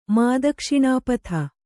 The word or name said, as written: ಮಾದಕ್ಷಿಣಾಪಥ